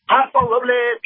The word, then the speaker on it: Paso Robles: We have calling from Paso, I'm going to butcher this, Paso Robles? – Paso Robles, California.